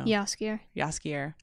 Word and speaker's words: Jaskier: Jaskier.